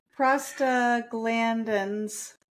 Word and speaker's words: prostaglandins: Google how to say proglastidin. – Prostaglandins. – Prostaglandins.